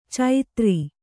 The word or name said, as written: ಚೈತ್ರಿ